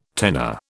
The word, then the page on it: tenor: British English